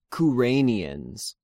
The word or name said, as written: Kyreneans